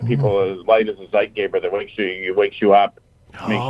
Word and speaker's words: Zeitgeber: Zeitgeber.